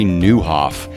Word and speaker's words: Nieuwhof: Today, I'm so excited about our guest, Carrie Newhoff. The last name is spelled N-I-E-U-W-H-O-F.